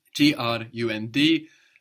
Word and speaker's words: Grund: G R U N D. And that D pronounced like a T. Grund. Round. – Rund. – Rund. Good. And what was soon? – Bald. – B-A-L-D, bald.